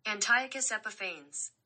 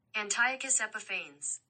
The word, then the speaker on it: Antiochus Epiphanes: Antiochus Epiphanes. – Okay, Antiochus. Antiochus. They say it, the last part, different. – Antiochus Epiphanes.